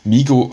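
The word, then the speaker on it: Migros: Ähm, Migro, vielleicht by the way, ist ein französisches Wort, darum spricht man das S nicht aus.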